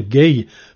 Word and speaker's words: Gaye: Alors Marvin Gaye, alors on prononce bien Gaye parce qu'il y a un E à la fin. Alors de son vrai nom, il n'y a pas le E à la fin. Mais pour éviter toute ambiguïté, Marvin a préféré qu'on rajoute un E et qu'on prononce Gaye.